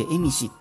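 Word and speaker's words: 蝦夷: エゾって書いてエミシって読む、あの、方々ですよね。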